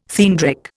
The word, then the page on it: theandric: Indian